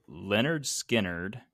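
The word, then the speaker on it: Lynyrd Skynyrd: So we did for the album Leonard Skinnerd. Pronounced Leonard Skinner. – Good job.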